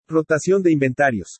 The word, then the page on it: ROTACION DE INVENTARIOS: spanish